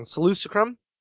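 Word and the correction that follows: Saleucami: You're talking about Seleucami, the one that's... – Yes! Yes, Seleucami.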